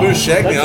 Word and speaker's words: Labuschagne: Oh, Labuschagne.